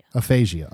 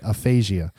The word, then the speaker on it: aphasia: Well, we found out, like everyone else in the world, that Bruce Willis has retired from acting because of aphasia. I think I said that right. – Aphasia. I thought you said something about Frasier. – I'm sorry. Aphasia.